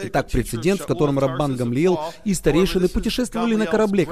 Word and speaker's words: Гамлиел: Итак, прецедент, в котором Раббан Гамлиел, вы, наверное, подумали, что это имя произносится Гамалиел, но корректное еврейское произношение Гамлиел.